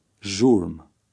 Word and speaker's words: Zhurm: In number 11, the letter combination ZH is pronounced like the S in the English word pleasure. Read number 11. – Zhurm.